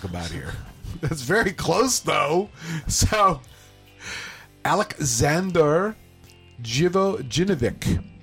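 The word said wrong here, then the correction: Živojinović: I think you're leaving out a syllable. – No. Jivojinovic. – Yeah, there you are. – Yeah, did I mispronounce it? I'm sorry. – You got it. No. When you first did it, you left out one of the syllables. You may have left out a couple syllables.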